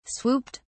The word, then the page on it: swooped: British English